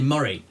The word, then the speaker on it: Moray: Murray, it's spelled M-O-R-A-Y on the bottle, but it's actually Murray.